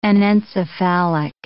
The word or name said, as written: anencephalic